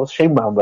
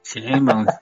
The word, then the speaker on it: Sheinbaum: La jefa de gobierno, Claudia Chainbaum, ah, no, Sheinbaum, ¿verdad? ¿O cómo se dice? – Sheinbaum, Sheinbaum, por favor.